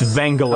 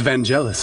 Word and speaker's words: Vangelis: If the Wikipedia entry is correct, and if I interpreted all those phonetic pronunciation symbols right, it's Vangelis. – I always thought it was Vangelis.